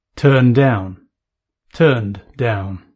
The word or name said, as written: turn down